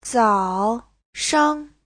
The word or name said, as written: zao3shang5